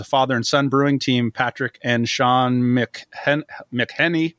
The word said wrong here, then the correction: McElhenney: I'll mispronounce that. – McElhenney. – Thank you. That's even better.